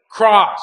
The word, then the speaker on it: Cross: My name is Cross. – I'm sorry, could you spell it? – Cross. C-R-O-S-S.